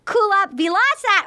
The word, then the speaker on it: Kulap Vilaysack: She is the director of the documentary Origin Story, Kulap, Vilaysack.